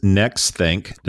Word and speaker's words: Nexthink: This is NextThink. Did I say that right there? Guardian of Gotcha. – I think so.